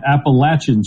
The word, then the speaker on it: Appalachian: We got corrected on the Eagle Hour talking to the App State people. You said it right, Appalachian State.